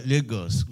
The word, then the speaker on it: Lagos: Well, Lagos, we call it. But basically, it's easier to say I'm from Lagos. – Okay. – Because almost all Nigerians reside one way or the other in Lagos, though Nigeria is fairly the size of Texas.